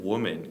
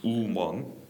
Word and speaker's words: woman: 우먼. – 네. – 네. 근데 우는 아니죠. 이렇게 와 이런 소리 들어야 돼요. 그래서 와우 생각해 보세요. Woman, woman, woman.